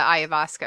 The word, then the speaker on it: ayahuasca: The ayahuasca or whatever. – Mm-hmm. – However you say it. Did I say it right? – Ayahuasca. – Ayahuasca.